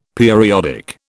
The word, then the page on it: periodic: British English